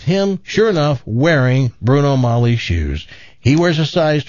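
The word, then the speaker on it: Magli: It was from a size 12 Bruno Mali shoe. It's M-A-G-L-I, but I think it's pronounced Mali. The G is silent.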